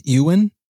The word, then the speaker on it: Ieuan: And we've come to the agreement, Aaron, that his name is Ewan. Spelled Ewan. I-E-U-A-N, Ewan Barrett.